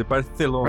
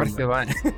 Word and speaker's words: Barcelona: Não pode falar Barcelona, então. Tem que, tem que fazer aquele C com, com linguinha presa. Tem que fazer Barcelona. Barcelona.